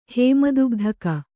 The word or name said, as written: ಹೇಮದುಗ್ಧಕ